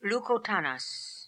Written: Lookotanas

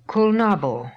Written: Kulnabo

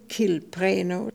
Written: Kilpreno

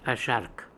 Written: Ashhark

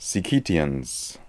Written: Sikitians

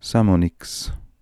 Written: Samoniks

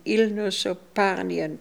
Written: Ilnosoparnian

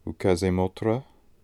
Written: Ookazemotra

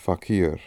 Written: fakir

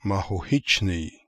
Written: Makhokhitchne

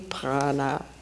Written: Prana

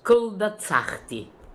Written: kldatsakhti

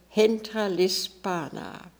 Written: Hentralispana

